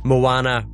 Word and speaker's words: Moana: Is it B, Mona? Is it C, Jaws? – Okay, I think it's A, Rocky. – You do get an extra mark if you can point out that I pronounced Moana wrong.